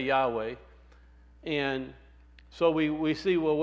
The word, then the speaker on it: Yahweh: If it's Y-H-V-H, where do we get the W? Because in classical Hebrew, that's modern Hebrew. In classical Hebrew, it would be a W because it's Wow, not Vav. And so that's where Yahweh came in, by putting the E vowel sound and the A vowel sound in there, you get what? Yahweh.